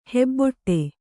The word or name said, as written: ಹೆಬ್ಬಟೆ